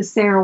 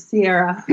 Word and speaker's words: Cerra: So there's lots of amazing benefits to drinking the Sera water, and I apologize, I said it wrong in the beginning. – You did? – I think I called it Sierra. I've renamed it. – Okay. C-E-R-R-A, water.